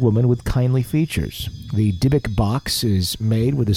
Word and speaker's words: Dybbuk: Is that how you say it? Dibbuk? – Dibbuk.